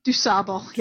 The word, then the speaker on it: DuSable: How do we pronounce it? If you're in Chicago, it's Dusable, yeah. – Yeah, I always learned it as Dusable.